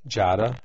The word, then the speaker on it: Giada: Jada, just like Jada De Laurentiis. – G-I-A-T-T-A. – G-I-A-D-A.